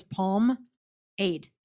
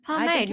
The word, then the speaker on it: pomade: I really thought it was palm aid. – Well, because you thought it was that, I guess I am pronouncing it right, but I always wondered. It's a weird looking word. So I'm like, is that how you say it? Pomade? – Huh.